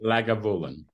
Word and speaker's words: Lagavulin: The Lagavulin, is that how you say it? Lagavulin? – Lagavulin.